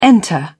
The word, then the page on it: enter: British English